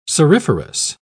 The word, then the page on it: cirriferous: British English